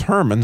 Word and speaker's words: Hermon: my father's father's name was Hermon, spelled like that. It's H-E-R-M-O-N, not like Herman Munster, H-E-R-M-A-N. But Hermon, and that mountain, those letters have to do with the start. You hear the R in there, that resh, R, that her, like you heard the sure, right?